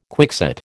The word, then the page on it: quickset: American English